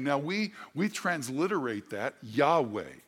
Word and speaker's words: Yahweh: How do you say that? Well, it sounds like Yahweh.